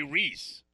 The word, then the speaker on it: Rees: Tommy Rees. That's not how you pronounce it. It's really Tommy Rees.